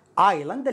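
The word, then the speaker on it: island: تنطق ايلاند الاس لا ينطق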